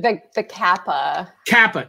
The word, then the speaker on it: Kappa: So you went to Japan and you were investigating the Koopa. Is that how you pronounce it? – The Kappa. – Kappa. All right.